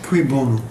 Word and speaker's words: Cui bono: How do you say that? – Cui bono.